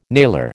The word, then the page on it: nailer: American English